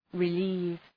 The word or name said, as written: relieve